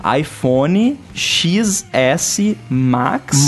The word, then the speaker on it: iPhone XS Max: E aí agora, cara, você tem um, um device que vai se chamar iPhone XS Max, Max. Mas, mas que o X você pronuncia como 10 e, cara, iPhone, pronto.